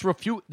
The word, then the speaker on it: Refugio: It's pronounced refugio. The G is pronounced like an H, right? In refugio.